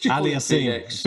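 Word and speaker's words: Auger-Aliassime: Am I going to get the name right? Alyssiame? Or is it? – Yeah, just Aliassime. – Felix, Oja, Aliassime. That's it. I get it right. I keep on saying Aliassime. It's not right.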